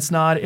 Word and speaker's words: Snaadt: My name is Ryan Snaadt. It rhymes with odd, and you're listening to the Rhymes with Odd podcast.